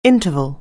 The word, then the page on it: interval: anglais